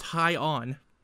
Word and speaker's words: Hyan: Apparently Han's name is indeed pronounced High On. It's spelled H. It is spelled H Y A N. Apparently, so. Or that might be a typo in my notes. – It sounds like High End.